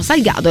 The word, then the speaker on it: Salgado: Tra l'altro è Sebastiano Salgado, scusate, la giusta, no, Salgado è la giusta pronuncia.